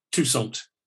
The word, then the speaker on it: Toussaint: Is it, how do you pronounce his surname? Toussaint? – Alan Toussaint. Toussaint. – Yes, Toussaint. – Alan Toussaint. That's where the emphasis is.